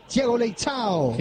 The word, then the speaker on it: Thiago Leitão: Lo pronuncié bien. Thiago Leitão. – Qué bien. Como le enseñó Nataluti el portugués, ¿no? – No, no, yo aprendí bien. Yo aprendí muy bien.